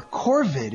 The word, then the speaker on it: corvid: And these are, I don't think I've said this word before, corvid. Is that how you say it? – Yes.